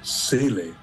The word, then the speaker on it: Seele: They make a long E, Seele, like Michael Soul, but there's only one E. So I have always to say Michael Seele, only with not Soul, Seele, like with one E.